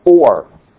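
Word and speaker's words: Or (אור): It's pronounced Or. Or, O-R, Or, light. There's a Hebrew word, Aura.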